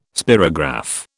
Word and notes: spirograph: British English

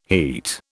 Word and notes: heat: British English

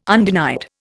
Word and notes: undenied: Indian

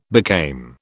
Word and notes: became: British English

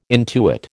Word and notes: intuit: American English

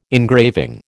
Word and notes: engraving: American English

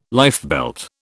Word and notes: lifebelt: British English

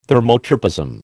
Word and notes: thermotropism: American English

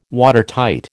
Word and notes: Watertight: American English

